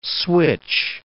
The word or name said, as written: switch